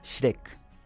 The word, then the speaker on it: Schreck: Schreck. – Schreck. Como logro. – Como logro, como el de las películas. Schreck, en realidad, cuando los alemanes... – Supongo, te pegaste un Schreck.